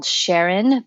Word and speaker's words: Charon: called Sharon, but it's spelled Charon,